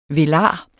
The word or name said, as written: velar